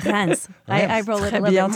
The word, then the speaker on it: Reims: And then you have to pronounce the S at the end, which usually we don't. We don't say Paris, we say Paris. – Right. – But we say Reims, so it's the exception.